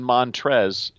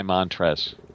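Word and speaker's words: Montrezl: By the way, the L in Montrez is silent.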